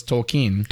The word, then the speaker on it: Tolkien: You got to do the Well, you wouldn't say Keen with a different inflection. You would just say Keen, so it's Tolkien.